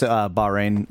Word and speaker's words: Bahrain: Someone mentioned that the X-Shot long shot was available in Bahrain, which I think I'm pronouncing that correctly. – It's just Bahrain, but, you know. – You can anglicize it.